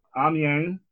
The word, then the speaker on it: Amiens: He last played for a team that by American linguistics looks like Amiens, but is pronounced Amiens.